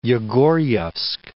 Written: Yegor'yevsk

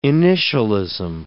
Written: initialism

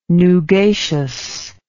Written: nugacious

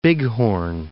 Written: Bighorn

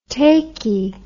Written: taky

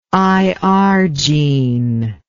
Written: irgene

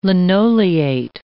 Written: linoleate

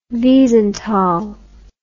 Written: Wiesenthal

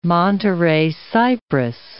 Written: Monterey cypress

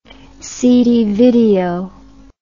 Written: CD video

